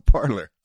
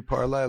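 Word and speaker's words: Parler: I thought it was meant to be Parlay, like we parlay, we talk. – No. – It's flat out Parlor? – It's Parlor. – But it's spelled wrong. – Well, I can't help you there. – It's P-A-R-L-E-R. – Yes, Parlor.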